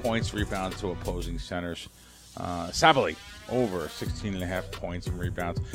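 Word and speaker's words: Sabally: Sabali, yeah.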